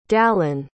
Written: dallon

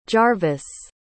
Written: jarvis

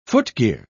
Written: footgear

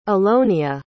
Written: allonia

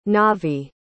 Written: navi

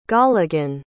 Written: golligan

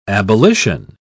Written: abolition

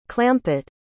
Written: clampitt

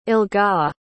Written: elga

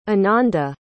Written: anandi

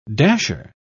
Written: dasher

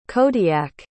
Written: kodiak

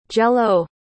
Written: Jell-O

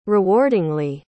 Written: rewardingly

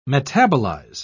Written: metabolize

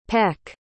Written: pec

